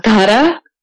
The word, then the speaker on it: Dhara: Dhara.